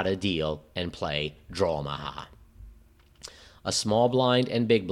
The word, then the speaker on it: Drawmaha: This small intricacy leads to why this game goes by the name of Drawmaha instead of Dramaha and goes by an alternate name, Sviten Special.